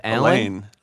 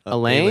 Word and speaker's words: Alain: Now, the name is spelled A-L-A-I-N. You think that's Alan? – Elaine. – Elaine? – Yeah.